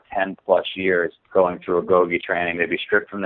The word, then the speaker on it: Agoge: Let's just start with, so what is Agoge? First of all, am I pronouncing it correctly? – You are pronouncing it correctly.